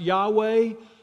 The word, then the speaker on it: Yahweh: And translated those four letters, the tetragrammaton, if you want to hear some, you know, real highfalutin sounding words about it, YHWH, those Hebrew consonants that are pronounced Yahweh.